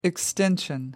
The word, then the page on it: extension: American English